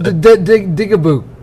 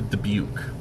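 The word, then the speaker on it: dybbuk: I think it's pronounced digaboo. – Digaboo. – Close. I believe it's pronounced a Dubuque box. – Yeah. – I might have the pronunciation wrong, but it's D Y B B U K.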